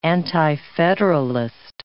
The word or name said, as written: anti-federalist